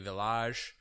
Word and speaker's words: Villages: I can never say any of this stuff, but it's a Debune Special Selection Beaujolais Village. So the Beaujolais Village is it's spelled villages, so but they say village.